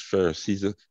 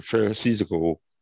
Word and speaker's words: Pharisaical: Well, you know, what gets me is that a lot of us live in this Pharisaical, let me pronounce it right, Pharisaical type of approach.